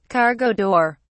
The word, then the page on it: cargo door: British English